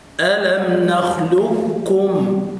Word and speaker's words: نخلقكم: أنت أنا أقول لك أن تنطق الكاف نخلقكم كم